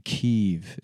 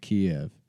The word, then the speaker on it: Kyiv: And the new thing now is saying Kyiv instead of Kiev.